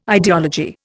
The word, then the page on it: ideology: Indian